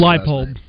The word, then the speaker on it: Leipold: Leipold.